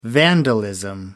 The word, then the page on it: vandalism: American English